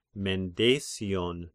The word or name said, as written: Mendesian